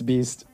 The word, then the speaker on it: Beast: I always forget how to pronounce this. – Beast? – Best. – It's Beast. – Beast. That's what it is.